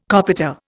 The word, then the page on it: copita: Indian